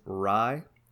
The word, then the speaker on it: Rhye: I didn't realize that I'm not super familiar with there's a guy named Rye, like the bread, but it's spelled R-H-Y-E.